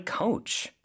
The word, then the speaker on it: coach: Well, I learned so much from my coach. 코치님께 정말 많이 배웠어요. – 아, 코치님이 my coach가 되네요. 그렇죠? coach, 발음이 조금 다르죠? 그래서 오 발음을 길게 해주시면 됩니다.